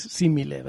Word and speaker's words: simile: pero he dicho similar y no es similar, no existe, es simile, pero...